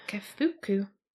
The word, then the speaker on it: Kafuku: There was a good chunk there, but I know, but in terms of the main lead character called Yusuke Kafuku, I think it's his last name, that's how you pronounce it. – Kafuku.